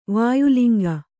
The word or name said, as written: ವಾಯುಲಿಂಗ